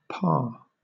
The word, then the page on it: parr: British English